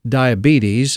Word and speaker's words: diabetes: Now, if you pronounce it diabetes, you're saying it wrong. If you left out the or sugar, you said it wrong. Southern grandmothers pronounced diabetes as diabetes, emphasis on di and us.